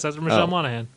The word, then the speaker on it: Monaghan: All right, so it's also starring Michelle Monaghan. Yeah, that's how you say her name.